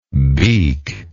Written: beaklike

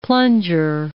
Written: plunger

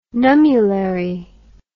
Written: nummulary

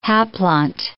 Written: haplont

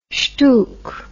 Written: shtook

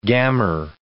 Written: gammer

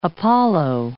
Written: Apollo

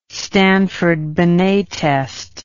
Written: stanford binet test

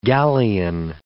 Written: galleon

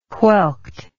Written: whelked

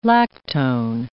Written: lactone

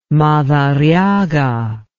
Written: madariaga

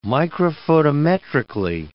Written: microphotometrically

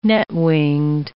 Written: net-winged

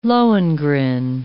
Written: Lohengrin

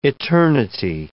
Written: eternity

